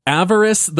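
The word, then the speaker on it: Avarice: So we got to fight, I'm still not sure how to pronounce it, Avaris the Gold Cursed, I believe yep.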